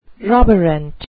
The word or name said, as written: roborant